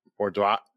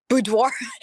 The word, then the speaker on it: boudoir: boudoir. How do you say that? – Boudoir. – Boudoir.